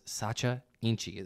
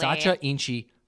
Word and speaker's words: Sacha Inchi: and I want to know more about this Sacha Inchi. Am I saying that correct? – You said it correctly. – Sacha Inchi protein.